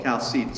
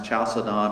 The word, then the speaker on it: Chalcedon: And so what happened was that the Council of Chalcedon, sometimes you hear it pronounced Chalcedon or Chalcedon, either way is fine.